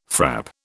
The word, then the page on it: frap: British English